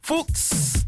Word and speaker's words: Fuchs: Folks, that's F-U-C-H-S, pronounced folks.